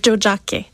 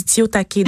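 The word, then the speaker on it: Tiohtià:ke: Euh, puis oui, ça s'appelle Jojake. Jackie, c'est comme ça qu'il faut le prononcer. Moi, j'ai dit Tiotake.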